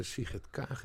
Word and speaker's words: Sigrid Kaag: Ja, het is een soort rechtse Sigrid Kaag inderdaad.